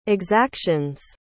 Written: exaction's

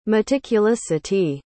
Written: meticulousity

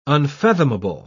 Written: unfathomable

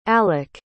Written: alec

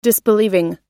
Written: disbelieving

